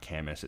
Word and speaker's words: Camus: It's not Camus. It's spelled Camus, C-A-M-U-S.